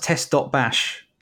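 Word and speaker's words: TestBash: Is it pronounced test.bash? – It is, yeah.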